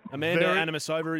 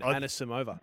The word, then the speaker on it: Anisimova: Anisimova is the last one of those. – Well, that's the one I'm really excited about. – I actually said it wrong again. Anisimova.